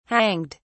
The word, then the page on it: hanged: British English